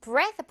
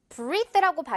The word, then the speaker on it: breath: 이럴 때 이제 이 breath 부분을 breathe라고 발음하면 안 된다는 거. breath, 에 발음이라는 거 여러분.